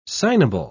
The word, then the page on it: signable: British English